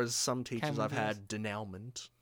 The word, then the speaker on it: denouement: you know, said denouement.